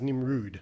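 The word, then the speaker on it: Nimród: Now, I've got to get this name right because if you say his name as it's spelt, it just looks like Nimrod. But I believe it's pronounced Nimrud. It's a foreign name. It's got a little accent on it.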